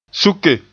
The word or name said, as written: suke